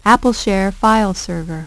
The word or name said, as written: AppleShare File Server